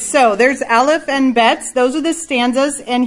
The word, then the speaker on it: Bet: But the next one might throw you off because it is not pronounced like Mrs. Jones' name. Bet. Yep. So you're going to ignore that H there.